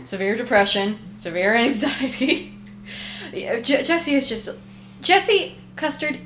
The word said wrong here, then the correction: Custer: is a Custer. – Custer. – You said Custard.